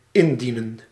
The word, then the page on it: présenter: [pʁe.zɑ̃.te]; France